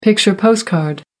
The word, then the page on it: picture postcard: American English